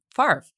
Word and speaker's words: Favre: It's Brett Favre, whose last name is very difficult to pronounce, or it's not, but like it looks weird. It's F-A-V-R-E. Why is it Favre? Why is the F where it is?